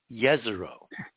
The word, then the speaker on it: Jezero: Yeah, except the J on Jezero is supposed to be pronounced, according to our sources, as a Y, Jezero.